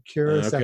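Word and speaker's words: Curaçao: Think of cure a pig. Curacao.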